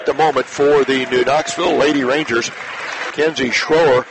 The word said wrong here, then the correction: Schroer: or I should say Schreyer, is the correct pronunciation. Kenzie, a fine junior performer, spelled S-C-H-R-O-E-R, pronounced Schreyer.